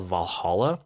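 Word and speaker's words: VA-11 Hall-A: But I'm in the middle of, and I actually moved this up from my geekery for the week, but there's a game called Valhalla, but it's not spelled like Valhalla. Do you see it in the show notes right there? – Yeah, it's VA11-Hall-A.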